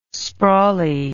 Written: sprawly

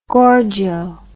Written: gorgio

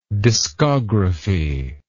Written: diskography

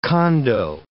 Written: condo

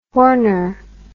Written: horner

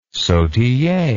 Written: sautille

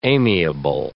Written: amiable

